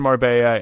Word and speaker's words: Marbella: and I am pronouncing that correct, right? It is Marbella, the double L. Mm-hmm. yeah. – Yeah, you got it. – Um, she arrives in Marbella,